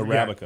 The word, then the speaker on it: Arabica: Air Arabica. Am I saying this right? – I have no idea. – Arabica, but you're Arabica. Yeah. There we go.